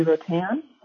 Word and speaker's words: Rotan: My name is Peggy Rotan. It's spelled R-O-T-A-N.